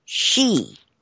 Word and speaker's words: sidhe: According to those who speak the Gaelic tongue of Scotland and Ireland, the wee folk prefer to be known as S-I-D-H-E, also spelled S-I-D-H, S-I-T-H, S-I-T-H-C-H-E, and pronounced she, S-H-E-E.